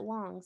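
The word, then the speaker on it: Wang: It's an A though, right? I'm not. – It is an A, but he pronounces it Wong, so I'm not sure.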